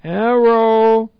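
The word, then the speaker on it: Erotao (ἐρωτάω): A future active indicative of the verb erotao. Erotao. This is E-R, long O, T-A, long O.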